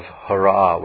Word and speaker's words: Hara: Now the word Hare with the E on the end is the vocative of Hara with a long A.